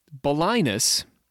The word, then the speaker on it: Balinus: Balinus is, which rhymes with sinus, is a guy, is a person.